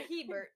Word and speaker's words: Hebert: H-E-B-E-R-T. – Herbert. – No, but there's, it's Hebert. – H-E-B. – Hebert.